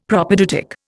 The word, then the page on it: Propaedeutic: Indian